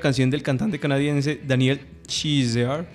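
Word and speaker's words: Caesar: canción del señor Daniel Kaiser, que... ¿Cómo es? Daniel Chizar. Es, como, ese es, en inglés se pronuncia como Chizar.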